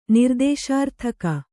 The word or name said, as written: ನಿರ್ದೇಶಾರ್ಥಕ